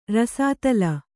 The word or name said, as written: ರಸದಾಲೆ